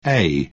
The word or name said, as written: say